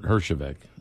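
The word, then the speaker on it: Herjavec: So the partnership with Robert Hershevik, did I say it right? – Yes.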